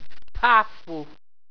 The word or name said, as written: ptaffu